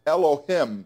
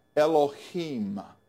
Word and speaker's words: Elohim: Now the title of his office, God, is spelled E-L-O-H-I-M. It looks like it's Elohim, doesn't it? It's actually pronounced Elohim, Elohim.